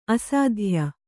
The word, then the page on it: ಅಸ್ಥಾಯಿ: Kannada